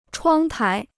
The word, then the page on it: 窗台: chuāng tái